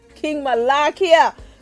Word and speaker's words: Malakiyah: King Malakiyah.